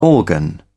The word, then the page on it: organ: British English